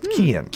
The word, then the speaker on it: Cian: Instead of Charles, they're naming him Cian, or Kian. Kian. C-I-A-N. It's pronounced Kian.